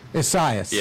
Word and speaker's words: Isaias: Isaias? How do you pronounce it? – Isaias is what I've been hearing, but it's a tough one for sure. – It's Isaias. – Yeah. – How would you pronounce it, my Latino friend, my Guatemalan friend? – Just Isaias, honestly.